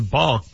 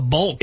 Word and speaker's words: balk: It's B-A-L-K, and the L is silent.